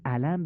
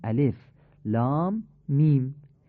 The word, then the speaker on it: الم: علم به صورت یک آیه آمده، یه آیه مستقل، دیگه نباید علم بخونیم، باید بخونیم الف لام میم، یعنی هر حرف رو با اسمش با نامش تلفظ کنیم.